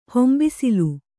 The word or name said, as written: ಹೊಂಬಿಸಿಲು